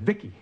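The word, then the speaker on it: VICI: V-I-C-I, I pronounce it Vicky.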